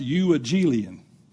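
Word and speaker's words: euangelion (εὐαγγέλιον): And I'm not calling you names, but the word is pronounced euangelion.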